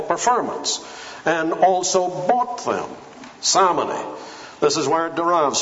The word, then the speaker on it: simony: Now, We know from church history there is such a thing as simony. Some people pronounce it simony. Simony.